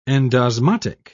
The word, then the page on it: endosmotic: British English